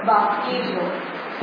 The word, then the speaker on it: baptizo: Baptizo.